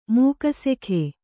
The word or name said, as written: ಮೂಕಸೆಖೆ